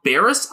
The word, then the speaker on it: Barras: Tom Barras, I believe it actually is. I'd been vacillating between that and Barras on the pronunciation, but apparently he pronounces it like the final two syllables of embarrass.